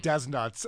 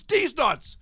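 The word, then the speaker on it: Dez Nutz: And he changed his name to D's Nuts, spelled D-E-Z. Dash N-U-T-Z. – He spelled his joke name wrong and it became Des Nuts. And everyone was like, why are you Des Nuts? He said, no, it's D's Nuts.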